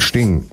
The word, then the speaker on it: Sting: So, man muss es so nämlich aussprechen. Sting, wie meine Oma.